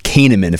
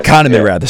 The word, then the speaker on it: Kahneman: The two researchers that you'd reference, Tversky and Kahneman, if I'm pronouncing that right. – Kahneman. – Sorry, Kahneman.